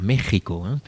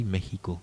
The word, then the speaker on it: México: Si vous êtes espagnol, vous auriez envie de dire México. Donc le X de Mexico, en fait, lorsqu'on le prononce sur place, se prononce CH. C'est le vieux CH précolombien. Donc on dit México.